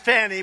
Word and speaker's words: Fani: F-A-N-I. That's pronounced Fanny, but you're not allowed to say it. It's Fani.